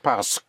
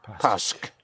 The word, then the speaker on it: parce: The S into the park makes it parce. Parce. Parce. Yes. Right. In spelling, it's spelled P-A-R-C-E-Q-U-E in two words. Parce. – Parce. – But in speaking, think of the S in the park.